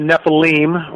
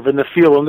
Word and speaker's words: Nephilim: I'm a Nephilim, or a Nephilim, depending on how you want to pronounce it.